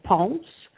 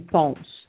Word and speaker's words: ponce: It's called une ponce. – How do you spell that word? How do you spell that word? – P-O-N-C-E. – Ponce, like Ponce de Leon or something.